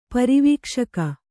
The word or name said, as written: ಪರಿವೀಕ್ಷಕ